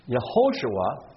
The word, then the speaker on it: Yehoshua: Joshua, the son of Nun, pronounced in Hebrew, Yehoshua. And after the Babylonian captivity, Yeshua.